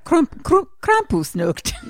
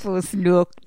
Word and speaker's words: Krampusnacht: Is that how you say it? Krampusnacht. – No, I think that's exact. – Krampusnacht. – Honestly, like, I think that was perfect. – It's Krampus day. – Night in German is Nacht.